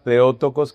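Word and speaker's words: Theotokos: Algunas personas pronuncian Teótocos. Es Teótocos, la palabra Teótocos que empieza con TH, o Teótocos pronunciemos, significa la que da luz a Dios.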